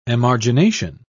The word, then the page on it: emargination: British English